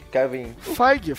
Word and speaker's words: Feige: Mike o Kevin Feige, como é que fala o nome? Kevin Feige? Kevin... Feige, Feige.